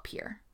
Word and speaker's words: Pierre: But it's spelled Pierre. It's Pierre, but people in South Dakota call it Pierre. – Oh. – And when you say it wrong, they get real offended, which I think is really funny because I do it now.